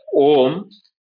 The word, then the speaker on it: Om: So the way to pronounce it is Om. There is a the nasal sound will remain as long as the air is going through the nostrils.